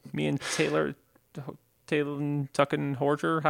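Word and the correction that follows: Talen Horton-Tucker: Talon Horton Tucker? – THT.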